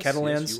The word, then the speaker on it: Catalans: Am I saying that right? C-A-T? – It does, yes. – Catalans? – Yes, you are saying that.